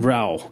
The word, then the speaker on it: Rowell: Rowling, Rainbow Rowell. – Rowell, okay. – And I remember that because Rainbow always says her last name rhymes with towel.